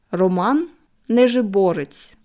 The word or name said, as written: Roman Nezhyborets